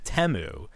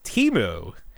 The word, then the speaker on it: Temu: Somebody this year they pronounced it Temu. But last year they had an ad where it was pronounced Timu, and that's how I'm going to continue to pronounce it, Timu.